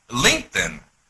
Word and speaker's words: LinkedIn: El, ED e ah. de link es como medio silencioso, entonces se pronuncia LinkedIn, que significa como en la rosca, ¿me entiendes?